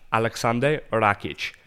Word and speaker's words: Aleksandar Rakic: Alexandre, I don't know how to say it. – Aleksandar Rakic. – Aleksandar Rakic.